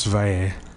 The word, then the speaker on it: Valle: Marcos Valle. – How do you spell it? – Marcos, M-A-R-C-O-S, and V-A-L-L-E. Valle.